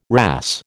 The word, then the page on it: wrasse: American English